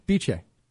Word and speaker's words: Bice: Biche. – Oh. Biche. – Biche. On Sherbrooke Street. It's spelled Bice.